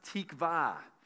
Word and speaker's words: tikvah: Now, the word hope in Hebrew is a really cool word. It's pronounced tikvah.